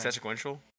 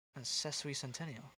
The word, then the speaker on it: Sesquicentennial: Don't you mean sequential? – Sesquicentennial. – Wait, let me see it. – That's how you say it.